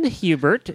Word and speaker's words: Huebert: His name is Ken Hubert. Is that how you pronounce it, Ken? – Yep, Hubert. Okay.